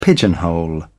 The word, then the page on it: pigeonhole: British English